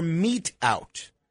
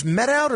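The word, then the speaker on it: mete out: You've been saying it wrong. – So what is it? – Meet out. – It's meet out. Yeah. Interesting. Okay. So meet out. meat out. You have to wonder about somebody that's using a word that he has no confidence in the pronunciation of. Meat out. Meat out, not met out.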